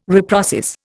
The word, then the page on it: reprocess: Indian